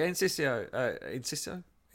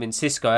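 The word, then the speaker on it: Enciso: Yeah, the Insisto. – I've called him Insisco, haven't I? I've added a C, and when there isn't actually a C, you are right.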